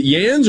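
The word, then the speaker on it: Jans: Is it Yans or Yans? – Oh, Yans. – Thank you. – It's a soft J.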